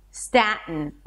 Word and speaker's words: Staten: Sta, like staff or stack. Sta. And then again we have the T schwa N. We already know how to pronounce it. Staten. Staten or Staten when I release it through the nose. Staten, do whatever is easier for you.